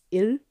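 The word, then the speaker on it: il (일): It's like ill, but you're not really touching the tip of your teeth. You're not touching your teeth with the tip of your tongue, excuse me.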